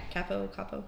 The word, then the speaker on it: capo: Yeah, the capo. Yeah. Is that how you pronounce that? – I think so, yeah. – Okay. – Capo? – Capo.